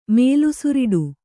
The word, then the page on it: ಮೇಲುಸಿರಿಡು: Kannada